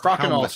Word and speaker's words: Crokinole: Apparently, I was pronouncing Crokinole crazy wrong, as apparently as well. Crokinole. I don't know. – I think you're doing it fine. – Thanks, Tempest. – Crokinole sounds about right.